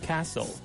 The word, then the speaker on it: castle: 不是不是，是 stle 是一个 soul 的音。 – castle，所以我要念 castle，not castle。